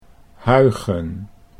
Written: Huygen

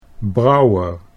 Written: Brouwer

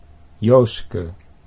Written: Jooske

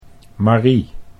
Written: Marie

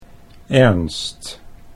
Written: Ernst

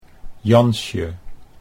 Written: Jansje

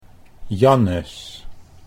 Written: Jannes

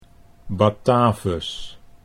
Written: Batavus

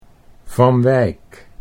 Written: Van Wijk

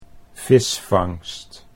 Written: visvangst

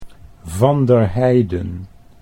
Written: Van der Heiden

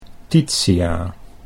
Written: Titia

